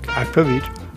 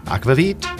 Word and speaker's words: Aquavit: Aquavit or Aquavit? How would you pronounce it, Henrik? – Aquavit. – Aquavit.